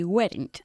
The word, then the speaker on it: weren't: No, they weren't. – Okay, you need to say weren't. – Weren't. – Not weren't. Okay, weren't. – Weren't. – They were, they weren't. No cambia, ¿vale? Es una sola sílaba.